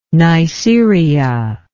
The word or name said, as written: neisseria